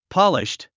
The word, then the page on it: polished: pάliʃt; ポリシュト